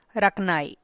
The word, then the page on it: raknay: Teotitlán del Valle Zapotec